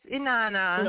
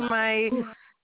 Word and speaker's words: Inanna: Yes, it's Inanna. – Okay. – Yes, actually, Inanna.